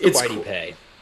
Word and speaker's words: Kwity Paye: It's Kwity Paye.